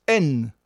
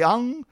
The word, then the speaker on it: En: Et ce personnage porte un titre masculin, Dominus, N, en occitan. Il ne faut pas prononcer An.